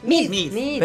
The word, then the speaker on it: Meade: Oye, se pronuncia Mid, entonces ya todo el mundo pronunciaba el lapido correcto.